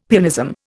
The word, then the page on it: pianism: Indian